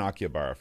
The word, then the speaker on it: Akihabara: is the three-floor store in Akihabara. Am I saying that right? – Akihabara.